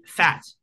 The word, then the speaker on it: Pfaadt: It's spelled P-F-A-A-D-T. That is a hard name to grow up with right there, because realistically, Pfaadt is the first thing that came to my mind, but it's Pfaadt. Pfaadt. You have to say it like Pfaadt, and you have to say it fast.